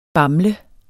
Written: BAMLENDE

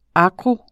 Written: agro.

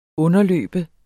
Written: underløbe

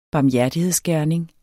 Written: barmhjertighedsgerning

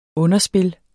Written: underspil

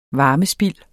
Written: varmespild

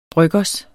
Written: bryggers

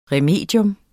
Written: remedium

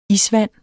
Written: isvand

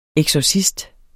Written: eksorcist

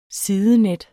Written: sidenet

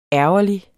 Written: ærgerlig

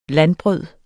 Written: landbrød

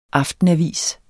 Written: aftenavis

